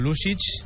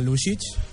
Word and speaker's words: Lušić: I avui ens acompanyen molt especialment Loto Lušić, que ell és professor de secundària, i la seva mare, Branka Lušić. No sé si ho he pronunciat bé, benvinguts. – Sí, has pronunciat molt bé, moltes gràcies.